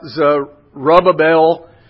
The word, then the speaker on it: Zerubbabel: both of these names actually have four syllables in them. So when I think about Zerubbabel, I think about rub-a-dub-dub, three men in a tub.